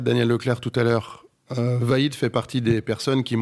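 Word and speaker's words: Vahid: Vaid Halilhodžić, Oui, ne te pose pas trop de questions et prononce-le le plus rapidement possible. – Coach Vaid.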